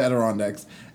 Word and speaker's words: Adirondacks: And we, well, because it's exclusive, but we went up to the Adirondacks and we had to go through, you're so stupid.